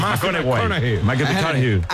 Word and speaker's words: McConaughey: Hay que ir corrigiendo la pronunciación. – McConaughey.